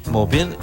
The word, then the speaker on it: Maupin: Es un libro que está escrito por Armistead Maupin, no sé si lo digo bien. – Maupin.